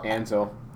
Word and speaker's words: Ansel: Ansel or Ansel? – Ansel. – Ansel, I know that.